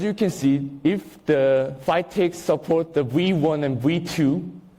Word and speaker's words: FCITX: So if you see this FCITX is pronounced as FITX, and the IBUS, these two are input method, so you need this to input things.